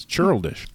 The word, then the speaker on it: churlish: Churl dish? – Yes. – It's a word? – Churl dish. – I don't think that's the pronunciation. – No, it's from Key and Peele. – Yes. – It's churlish.